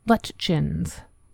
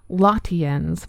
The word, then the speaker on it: Lutyens: The most common pronunciation of Elizabeth's name is Lutchins, with an accent on the first syllable and the Y pronounced like a CH, from a couple of BBC documentaries. I did encounter one British radio show where the name was pronounced Lotians, but I'm going to trust the BBC pronunciations.